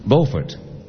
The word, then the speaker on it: Beaufort: In North Carolina, B-E-A-U-F-O-R-T is pronounced Beaufort. Next door in South Carolina, it's pronounced Beaufort.